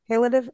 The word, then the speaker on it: palliative: So even down to that level of detail, Well, I had to learn what palliative care is. Palliative, am I saying it right? – palliative care.